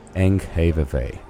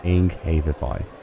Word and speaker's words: Enghavevej: And the following song, the one that you will hear next, is called A Memorial Garden on Enghavevi. That's better. Enghavevi, which I think is the proper Danish pronunciation of that street.